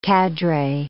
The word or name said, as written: cadre